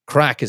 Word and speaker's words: craic: Now, for anyone out that has never been to Ireland and thinks that we're talking about something dodgy, crack is spelled C-R-A-I-C, and you're going to hear an awful lot on this podcast.